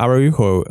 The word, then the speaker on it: Araujo: Yeah, right the at the end, where Araujo, like, which is how you actually pronounce his name. We've always said Araujo. Araujo. Our apologies.